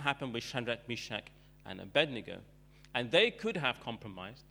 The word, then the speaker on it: Abednego: That name gets so mispronounced. Bendigo and all kinds of things. But Shadrach, Meshach, and Abednego, and their story, they did not bow down to the idol.